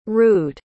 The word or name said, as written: rued